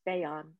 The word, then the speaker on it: Beyan: It's very long, but my first name is Beyan. If you think of it as Bey and An, that'll get you there.